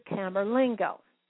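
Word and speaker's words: Camerlengo: It's C-A-M-E-R, Camer, and then Lengo. It's all one word, L-E-N-G-O. This is an official position in the Vatican. It's called the Camerlingo.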